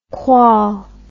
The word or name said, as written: qual